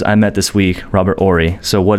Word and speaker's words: Horry: So I just hit a three-peat, fuck three hoes I met this week, Robert Horry. – Silent H.